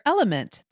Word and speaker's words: LMNT: Sarah, we're welcoming our sponsor, Element. That's spelled L-M-N-T,